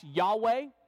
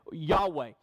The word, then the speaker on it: Yahweh: Sometimes you hear the divine name translated, or not translated, but pronounced Yahweh, which is taking the four Hebrew consonants that we see when the divine name is given in Scripture and making a guess at what the vowels are, because the vowels weren't in the original Hebrew Scriptures.